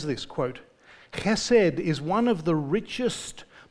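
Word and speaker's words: Chesed (חסד): The Hebrew word is spelled in English H-E-S-E-D, hesed, but it is pronounced with what seems like a rolling K at the front of it. Chesed, coming from the back of the throat.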